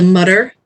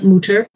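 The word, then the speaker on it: Mütter: Now you'll notice there's an umlaut over the U, so that is why we are not the Mutter Museum, but the Mutter Museum. I've been told that I do not pronounce that correctly if it were the German word, but we do our best.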